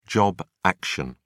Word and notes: job action: British English

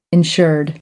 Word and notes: insured: American English

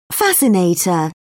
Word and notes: fascinator: British English

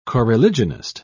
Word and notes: coreligionist: British English

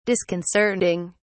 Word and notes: disconcerting: British English

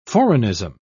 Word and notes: foreignism: British English

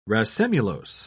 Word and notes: racemulose: British English

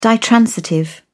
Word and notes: ditransitive: British English

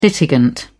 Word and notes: litigant: British English

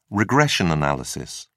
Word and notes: regression analysis: British English

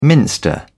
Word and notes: minster: British English

